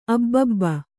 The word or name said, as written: ಅಬಬ